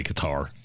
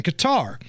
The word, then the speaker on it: Qatar: I'm going to say Qatar.